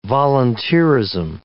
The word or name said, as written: volunteerism